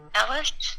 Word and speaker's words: Eilish: I think it's Billie Eilish. – No, it's not eyelash. Eilish? It sounds like eyelash, but it's not exactly eyelash.